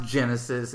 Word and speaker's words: Genisys: Terminator Genisys? How do you say that? – It's pronounced Genisys.